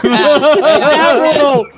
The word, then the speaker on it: adorable: Adorable. No, I said adorable, but it... I didn't put the emphasis on the right syllable.